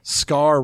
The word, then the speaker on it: Scar: Scar rhymes with star.